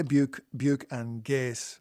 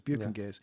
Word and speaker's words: Buke and Gase: And the guests are the Metropolis Ensemble, Aron Dyer, from, you're going to help me with this, because I play Buke and Gase on the show, but I'm never 100% sure I'm pronouncing the name correctly.